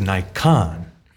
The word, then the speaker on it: Naikan: Um, but this is Naikan, um, which it should be pronounced that way in Japanese.